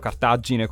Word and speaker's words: Cartagine: Io intanto lo pronuncio Cartagine con 2 G perché sono siciliano.